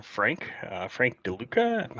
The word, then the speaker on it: Frank DeLuca: Yeah, this is Frank DeLuca.